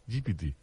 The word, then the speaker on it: GPT: Escolta, el del xat eh, GTV aquest em, em té flipat. – M'ha encantat, encantat la teva pronunciació, GPT. – Ah, GPT.